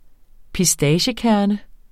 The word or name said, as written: pistaciekerne